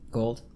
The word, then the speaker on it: Gould: Gould? Gould? How do you pronounce G-O-U-L-D? – Gold. – Gold? – Yeah.